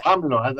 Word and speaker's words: AMLO: AMLO, that's how it's pronounced here.